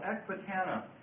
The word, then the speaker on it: Ecbatana: The first one is called Ecbatana.